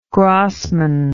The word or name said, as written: Grassman